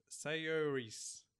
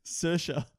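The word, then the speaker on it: Saoirse: Sayoris. – Yeah? – Yeah. But it's probably like Savris. – Oh. – Am I close? – Savris. – Yeah. Savris. Come here. – Sersha.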